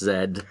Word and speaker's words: Z: Z. It's pronounced Zed.